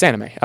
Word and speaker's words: Aminé: No, it's Aminé. Okay, is that the is that Caroline fella? – Yeah, Caroline. – That's Aminé. – No, it's it might be pronounced differently than anime, but it's spelled Aminé. – Never heard it. – It's spelled A-M-I-N-E with a little thing over the E.